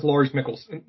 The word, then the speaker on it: Lars Mikkelsen: In terms of recasting, like this is Lars Mikkelsen, I pronounce his name, right? – Yeah.